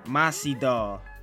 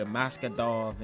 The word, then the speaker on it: Masvidal: Jorge Masvidal. Masvidal. I don't know why I always say his last name wrong. I used to call that nigga Maskadov and shit.